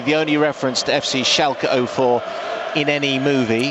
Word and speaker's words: Schalke: Schalke 04. – Well, Schalke, it's them.